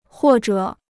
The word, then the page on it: 或者: huò zhě